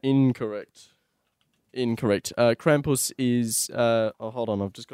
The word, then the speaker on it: Krampus: Krampus.